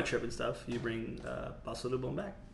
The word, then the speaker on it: pasalubong: The G is silent. – Pasalubong. No, you can say the G.